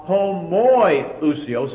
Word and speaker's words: homoiousios: Homoousios means of the same nature or the same substance, while homoiousios, the only difference is an O or an OI, is that middle syllable.